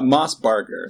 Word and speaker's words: Mossbarger: Moss Barger, so it's kind of hard.